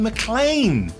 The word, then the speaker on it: McLean: Oh, Gareth McLean! – Yeah. – Hello, Gareth. – He's written at the bottom, pronounced McLean, just in case you weren't. Oh, pronounced McLean!